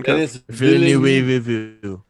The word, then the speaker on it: Denis Villeneuve: Speaking of directors, let's talk about Denis Villeneuve. Is that the correct pronunciation? – Denis Villeneuve.